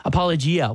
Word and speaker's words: apologia: I know some of them I'm going to botch it, but apologia, what is the Greek word there, and how is it used? – Yes, and it is pronounced apologia.